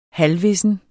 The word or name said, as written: halvvissen